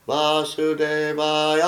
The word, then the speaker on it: Vasudevaya: Vasudevaya. – А вы все вот так поете. – You understand the difference? – Понимаете разницу? – I'm putting the emphasis on the next to the last syllable, and you're all putting it on the last syllable.